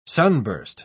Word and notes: sunburst: British English

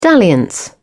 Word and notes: dalliance: British English

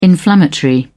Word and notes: inflammatory: British English

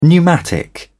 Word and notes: pneumatic: British English

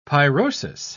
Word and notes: pyrosis: British English

